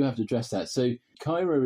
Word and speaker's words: Caerau: If you were in a different part of Wales, you would probably pronounce that word quite differently. You might say Cairai, but we're in the sort of the southwest suburbs of Cardiff, and there's a very particular Cardiff accent.